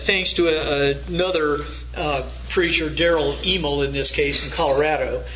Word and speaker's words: Hymel: But Daryl Emel is spelled H-Y-M-E-L, Daryl Emel.